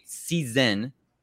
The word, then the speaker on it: SZN: And fellas, it's officially debate season, S-Z-N. That's how we pronounce it around here.